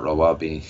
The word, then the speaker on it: Rawabi: Tell us about the planned community for affluent Palestinians, Rawabi, if I'm saying that correctly. – Yes. Rawabi is a very controversial city being built on a hill just north of Ramallah.